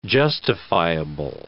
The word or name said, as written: justifiable